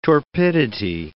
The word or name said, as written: torpidity